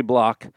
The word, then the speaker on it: Bloch: Wait, it's spelled spelled differently. Actually spelled differently. Yeah, B-L-O-C-H is Henry Block, and he was one of the co-founders of H&R B-L-O-C-K.